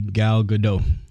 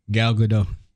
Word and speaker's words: Gal Gadot: So number five, it'll be Gal Gadot. – All right. See, now I got to look all these damn up. All these top. – Wait, who you say? – Gal Gadot. – Gal Gadot.